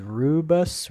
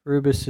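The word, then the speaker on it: Rubius: M-E-R-C-S Mirks. The number 9 is Rubus. I believe is how you pronounce that. It's R-U-B-I-U-S. Rubus.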